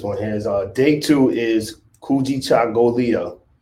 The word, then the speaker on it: Kujichagulia: But Kujichagulia. – Yeah, Kujichagulia. Kujichagulia.